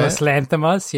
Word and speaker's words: Yorgos Lanthimos: Yorgos Lanthimos? Is that how you say it? – Yorgos Lanthimos, yeah.